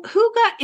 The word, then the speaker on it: Hygge: The first time I heard the word Hygge, which is spelled H-Y-G-G-E and pronounced Hygge, was a few years back when our daughter was in Copenhagen for a fall semester in college.